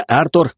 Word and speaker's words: Артур: «Я Артур, ударение на А, а вот тот с текущим носом, Лазарь».